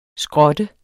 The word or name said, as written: skrot